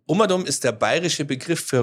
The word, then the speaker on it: Umadum: Ja, und das muss man auch wirklich so aussprechen. Es kommen viele und sagen, Umadum, das habe ich noch nie gehört. Ja. Es ist auf Gott bayerisch gesagt Umadum, also rundherum.